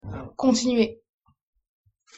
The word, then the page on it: continuer: French